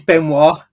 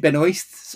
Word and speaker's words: Benoist: Because it's Benoit, not Benoist, but she does pronounce it Benoist.